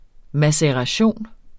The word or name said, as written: maceration